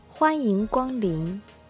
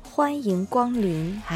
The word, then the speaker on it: 欢迎光临: これを続けて発音して欢迎光临。欢迎光临。